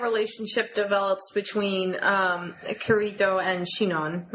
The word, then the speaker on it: Sinon: Because they, you know, it By the way, you are pronouncing it correctly. I've looked that up. – Okay. – That's the correct. It's spelled S-I-N-O-N. – O-N, but it's Shinon. – It's pronounced like Shinon.